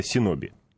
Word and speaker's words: синоби: Ниндзя — это синоби.